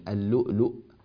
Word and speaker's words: اللؤلؤ: اللؤلؤ المكنون، هذه الكتابة لا أثر لها نهائيا في النطق، يعني اللؤلؤ هي لا فرق،